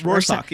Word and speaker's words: Rorschach: I never say that word. Did I say it right? Rorschach? – Rorschach, yeah.